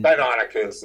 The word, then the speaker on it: Deinonychus: or you pronounce it Deinonychus, mm-hmm. – Deinonychus is how I pronounce it. – Okay, that's what I like too.